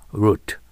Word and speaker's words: root: あるいは日本語でルールーと言うけど、英語の発音気をつけてね。 root、 uproot です。